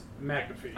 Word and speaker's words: McAfee: It's McAfee. – I think he pronounces his own name wrong. – You're an idiot. – It's M-C-A-F-E-E. McAfee. – Huh. – It's not Mac shit.